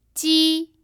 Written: ji1

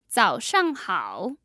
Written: zaoshanghao

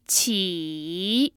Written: qi3

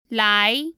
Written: lai2

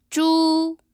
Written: zhu1

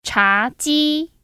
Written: ChaJi

